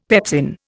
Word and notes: pepsin: Indian

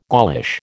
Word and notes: Gaulish: American English

